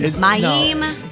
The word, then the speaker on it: Mayim: Mayim.